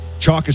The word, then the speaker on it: Choq: Chalk is spelled C-H-O-Q.